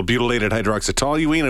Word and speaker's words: butylated hydroxytoluene: So he started going through, well, it could be a little bit of this, a little butylated hydroxytoluene. I went, that's how you say it. Butylated hydroxytoluene.